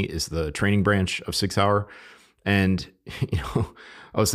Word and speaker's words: Sig Sauer: I want to recognize Sig Sauer, and that's the correct pronunciation, Sig Sauer, Sig Sauer.